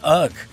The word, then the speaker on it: UGHH: And we are on the phone with UGHH, which is the canonical pronunciation, ladies and gentlemen.